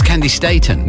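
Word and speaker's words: Candi Staton: It's Candy Staten. That is the way her name is pronounced.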